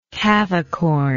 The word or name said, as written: cavicorn